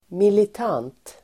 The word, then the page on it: militant: [milit'an:t]